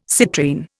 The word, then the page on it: citrine: Indian